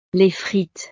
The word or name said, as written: les frites